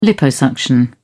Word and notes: liposuction: British English

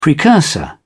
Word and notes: precursor: British English

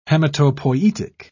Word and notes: hematopoietic: British English

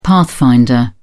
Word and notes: pathfinder: British English

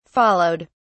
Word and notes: followed: British English

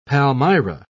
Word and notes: palmyra: British English

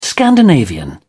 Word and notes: scandinavian: British English

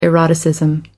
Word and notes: eroticism: American English

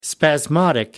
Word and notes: spasmodic: American English

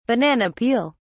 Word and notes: banana peel: British English

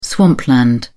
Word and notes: swampland: British English

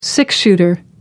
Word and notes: six-shooter: American English

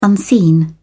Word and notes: unseen: British English

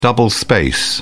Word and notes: double space: British English

